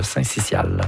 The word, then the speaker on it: syncytial: Non, selon le dictionnaire Usito, on prononce le syncytial. – Voilà.